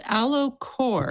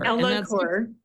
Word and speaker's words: Ellacor: That's called Elicor. – Elicor. – That's different than like microneedling. – Oh, yeah, that's a good question. So Elicor, it's spelled Ellacor.